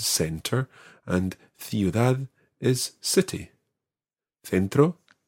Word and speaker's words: centro: So in Spain, it's th as in th. So you have sounds like centro.